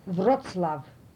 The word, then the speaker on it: Wrocław: WROCLAW. This is Wrocław in Polish language written, because this is a C.